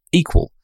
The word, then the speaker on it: EQL: That's EQL, pronounced equal.